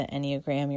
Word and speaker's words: Enneagram: And the Enneagram is, let me spell it for you as well first. It's E-N-N-E-A-G-R-A-M, Enneagram.